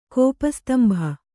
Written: ಕೋಪಸ್ತಂಭ

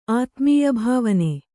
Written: ಆತ್ಮೀಯಭಾವನೆ

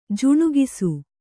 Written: ಜುಣುಗಿಸು

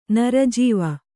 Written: ನರಜೀವ

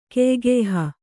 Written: ಕೆಯ್ಗೆಯ್ಹ

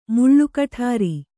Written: ಮುಳ್ಳುಕಠಾರಿ

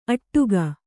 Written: ಅಟ್ಟಗೆ

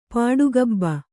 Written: ಪಾಡುಗಬ್ಬ

